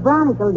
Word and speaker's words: bronchial: You heard Doc Gamble tell me if I didn't cut out cigars, my chronicle tubes would get stopped up and I would... – The word is bronchial, dearie, and it's pronounced bronchial.